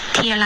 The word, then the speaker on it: till: Till I get back some of my massage clients. – 这个 till 注意元音不是一个 e，你刚刚说的 till，我们要 till。